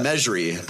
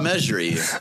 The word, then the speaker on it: Mejri: Salah Majiri. – Oh my gosh. – Or Mejri. Mejri, sorry. – Yeah, I think you butchered his name. – No, it's Salah Mejri.